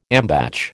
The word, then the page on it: ambatch: American English